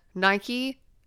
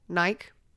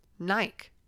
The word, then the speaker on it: Nike: Well, let me tell you, the rest of the world, Nike. Nike. It makes sense. I mean, think about like the name Mike. M-I-K-E. We don't say Mikey.